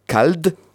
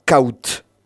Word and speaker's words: cald: Ama vai se segnalo que cald se prononce cal al nord de l'Aveyron et en standard caut. Voilà des différences entre l'Aveyron et le standard.